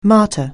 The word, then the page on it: martyr: anglais